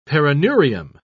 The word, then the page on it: perineurium: British English